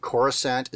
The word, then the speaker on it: Coruscant: So, like, though they might be in there, I think Coruscant is cool from Star Wars. – Yeah. – It's got that soft C.